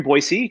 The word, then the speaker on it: Boise: So yeah, I live here in Boise. Good correction on the way to pronounce that. Well done on that.